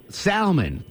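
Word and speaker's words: Thalmus: Salmon is in, Salmon? Is that your name? – No, it's Salmon. – Oh, Salmon. Chuck, how do you spell it? – It's T-H-A-L-M-U-S.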